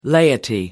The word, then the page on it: laity: American English